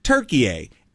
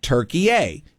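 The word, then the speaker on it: Türkiye: So Turkey-A is spelled T-U-R-K-I-Y-E. And there's those two dots over the U. All right? And it's pronounced Turkey-A.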